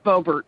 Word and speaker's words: Bobertz: It is Bobertz.